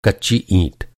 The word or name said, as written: ईं